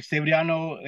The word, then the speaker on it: Severiano: Even Latinos have trouble saying my name. It's like man, it's like coming to a stage like Severiano, like they mess up my first name and then they'll say like no vale.